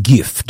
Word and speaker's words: GIF: With a hard G, like gift.